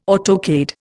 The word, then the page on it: autocade: Indian